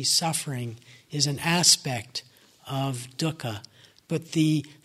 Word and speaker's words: dukkha: and the word is dukkha. It's spelled D U K K H A.